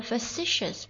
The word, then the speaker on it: facetious: 今天要跟大家学的第一个单词呢，它的前面四个字母是脸那个单词 face，但是它的念法是不一样的。这个单词读作 facetious， F-A-C-E-T-I-O-U-S facetious facetious，它是一个形容词，它表示什么呢？